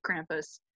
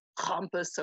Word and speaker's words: Krampus: We get things like, I can't say it correctly, I'm sorry, German friends, but Krampus, Krampus, or whatever you do it. Where Krampus isn't super wrong, you're fine.